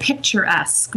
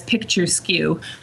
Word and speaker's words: picturesque: And the word picturesque, which I mispronounced it as a child as picture skew.